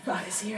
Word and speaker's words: Agassi: Agassi, right? Did I say that right? – Yes, very correct.